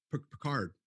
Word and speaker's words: Picard: So, you know, like the Star Wars, the Star Trek, you know, you got the new Pritchard, am I saying that right? No, the new Picard.